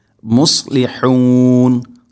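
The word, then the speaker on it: മുസ്ലിഹൂൻ (مصلحون): ഇവിടെ മുസ്ലിഹൂൻ എന്ന പദത്തിൽ പ്രത്യേകം ശ്രദ്ധിക്കേണ്ടത് സ്വാദ് ഹ ഈ രണ്ടക്ഷരങ്ങൾ, പ്രത്യേകിച്ചും സ്വാദിൻ്റെ ഉച്ചാരണം. ഇവിടേക്ക് പലപ്പോഴും, പലപ്പോഴും എന്നല്ല, അധികവും ഓതിപ്പോകുന്നത് മുസ്ലിഹൂൻ എന്ന് തന്നെയാണ്, അഥവാ സ്വാദല്ല, സ്വാദിന് പകരം സീനായിട്ട്. അത് ശ്രദ്ധിക്കണം. മുസ്ലിഹൂൻ, സ്വാ സൈ സു എസ്.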